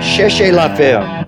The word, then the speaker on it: Cherchez la femme: Cherchez la femme.